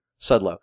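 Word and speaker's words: Szczudlo: Sudlow. – Yep. – It's spelled S-C-Z-U-D-L-O.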